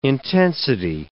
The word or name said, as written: intensity